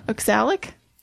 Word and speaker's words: oxalic: Oxalic.